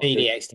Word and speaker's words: celiac: And so they've been brewing venture began when Jonathan, so that would be the son, I guess, was diagnosed with celiac disease. I'm sorry if I'm pronouncing this wrong. I've not... – Celiac disease, yeah.